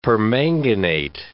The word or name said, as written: permanganate